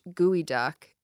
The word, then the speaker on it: geoduck: The geoduck, actually pronounced gooey duck, is a large clam native to the west coast of North America.